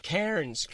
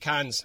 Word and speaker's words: Cairns: It's brilliant, but it's like saying, it's like trying to pronounce Cairns, and I just got used to saying it. I went to Cairns. – Yeah, I know. Because it's not like the way you spell it.